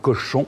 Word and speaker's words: Cauchon: C'est l'évêque de Beauvais qui va prendre les choses en main, monseigneur Cochon, l'évêque Cochon, euh, ça s'écrit C-A-U-C-H-O-N, euh, qui va lui faire son procès et qui, aux ordres des Anglais, va absolument vouloir la condamner.